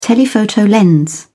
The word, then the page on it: telephoto lens: British English